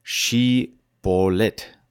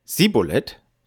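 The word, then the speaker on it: Schibbolet: Antwortete er dann mit Nein, so forderte man ihn auf, das Wort Schibolet auszusprechen. Sagte er dann Sibolet, weil ihm die richtige Aussprache unmöglich war, so ergriffen sie ihn und machten ihn an den Jordanfurten nieder.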